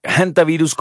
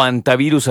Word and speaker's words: hantavirus: Hay personas que lo pronuncian de ambas maneras. Hantavirus, como con J, es en inglés porque es con H, entonces lo acentúan de esa manera. O hantavirus, así sin nada, también se vale y ese es en español.